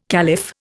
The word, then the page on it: caliph: Indian